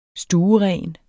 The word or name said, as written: stueren